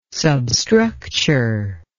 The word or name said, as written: substructure